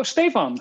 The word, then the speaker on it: Stefan: And since then, I just told everyone, you pronounce it as State Farm. And I actually had someone recently on a call, like mumbling, "Oh, State Farm." And then I was like, "Oh, Stefan, hi, nice to meet you." So that's kind of the way to pronounce it.